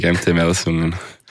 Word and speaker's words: MT Melsungen: Beim MT Melsungen, oder wie spricht man es aus? – Genau, das ist richtig, MT Melsungen.